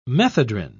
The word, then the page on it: methedrine: British English